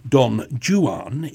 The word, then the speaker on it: Don Juan: Lord Byron, of course, introduced the English fashion of pronouncing it as Don Juan in his epic poem version a couple of hundred years later. And so annoyingly, that is the pronunciation that we are obliged to use in English concert halls, would you believe?